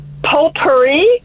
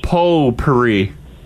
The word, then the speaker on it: potpourri: And then also potpourri. Potpourri. – Potpourri. – Potpourri. Well, potpourri. Because it is spelled pot. – Potpourri. – You don't know the T is silent.